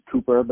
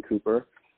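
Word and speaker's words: Couper: My last name's Cooper, but it's spelled with a U, C-O-U-P-E-R. So Kevin Cooper.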